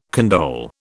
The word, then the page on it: condole: British English